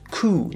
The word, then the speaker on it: coup: Okay, now this word here is pronounced coup, not coup.